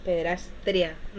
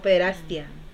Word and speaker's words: pederastia: Pederastria, no, pederastia. Pederastia. – Pederastia, sí.